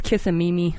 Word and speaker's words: Kissimmee: Yes, Kissimmee. – Kissimmee? – Kissimmee. That's how you spell it.